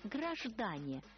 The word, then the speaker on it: граждане: Обыкновенная совпублика, пестрая многоликая масса, что носит у московских кондукторш название «граждане». Ударение на втором слоге в скобках указывает Булгаков.